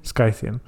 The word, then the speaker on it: Scythian: Scythian, Scythian. How would you pronounce that? – Scythian.